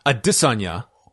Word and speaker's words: Adesanya: looking ahead to this weekend, we have UFC 248, Israel Adesanya. The official pronunciation, as said by the man himself, against Yoel Romero, one of your all-time favorites, Yoel Romero.